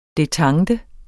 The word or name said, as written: detente